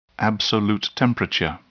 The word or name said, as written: absolute temperature